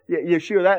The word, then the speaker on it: Yeshua: We say, when I use the word Yeshua, that's the Hebrew pronunciation of Jesus' name, Yeshua.